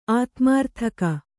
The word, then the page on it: ಆತ್ಮಾರ್ಥಕ: ātmārthaka